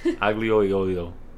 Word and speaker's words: aglio olio: Aglio olio. – Oh, wow. She butchered that. Aglio olio.